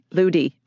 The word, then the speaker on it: Ludi: Ludi. – Okay, Ludi, like Lucy, but with a D, Ludi.